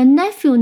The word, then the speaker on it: nephew: 那 nephew 呢是一个规则的读音，它的重音是在第一音节。